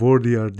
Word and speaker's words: Baudrillard: Ee, bu tabii yani hani ben tabii iletişim kuramlarını e, bilmediğim için Baudrillard diye okunuyormuş. Baudrillard, Baudrillard, Baudrillard, Baudrillard Baudrillard diye okunuyor dedi.